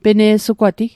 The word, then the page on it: pene'sugwati: Mi'gmaq